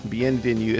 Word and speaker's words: Bienvenue: Bienvenue? Is that how that's pronounced? – Bienvenue.